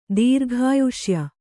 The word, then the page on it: ದೀರ್ಘಾಯುಷ್ಯ: kannada